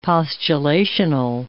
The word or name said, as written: postulational